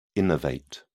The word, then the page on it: innervate: British English